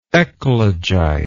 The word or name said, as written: eclogite